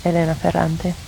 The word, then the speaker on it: Elena Ferrante: Elena Ferrante, Italian pronunciation, Elena Ferrante, born 1943, is the pseudonym of an Italian novelist.